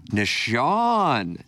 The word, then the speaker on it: Nashan: Chef Kevin, do you pronounce it nation or nation? – I usually say nation. – N-A-S-H-A-N. Nashon? – Nashon. Kevin Nashon.